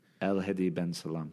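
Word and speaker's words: El Hedi ben Salem: Can you just give me his name so I can say it right? – El Hedi Ben Salam.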